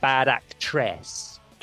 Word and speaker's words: Actress: I think Bad Actress was the one, though I think he pronounces it like Bad Actress. – Dress. – There's a tress, there's an emphasis on the tress in the song.